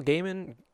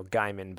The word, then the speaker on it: Gaiman: I just paused there because I'm like, wait, because I used to call him Neil Gaiman, but I've embraced now the correct pronunciation of Neil Gaiman.